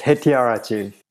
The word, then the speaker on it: Hettiarachchi: It's Hettiarachchi.